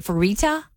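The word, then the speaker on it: Fertitta: It's F-E-R-I-T-T-A. Is it Ferita? – Yeah.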